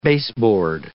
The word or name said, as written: baseboard